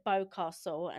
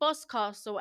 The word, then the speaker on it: Boscastle: I pronounced it Bowcastle, and a few Cornish locals got their pitchforks out and chased me to the car. No, they didn't really, but I felt like that. It's called Boscastle, and it's a little witches museum, and it cost about £7, wasn't it, for the adults? – Yeah, something like that, yeah.